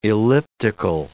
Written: elliptical

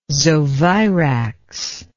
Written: zovirax

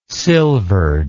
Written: silvered

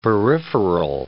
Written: peripheral

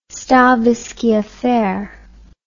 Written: stavisky affair

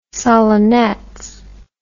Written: solonetz

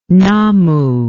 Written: nammu

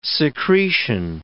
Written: secretion

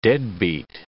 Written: deadbeat

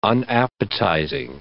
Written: unappetizing

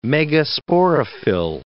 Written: megasporophyll

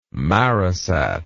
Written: marisat

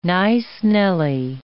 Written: nice-nelly

